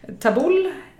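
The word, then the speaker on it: tabbouleh: Det var en quinoa tabulle, uttalar man det så? – Tabulle skulle jag säga.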